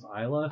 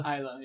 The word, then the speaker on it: Islay: I feel like the last time we had an Islay on the show, we figured out how to pronounce Islay. – Islay, yeah.